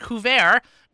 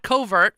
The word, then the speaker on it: couvert: And couvert, not covert, which is the way many people pronounce it, couvert is $72.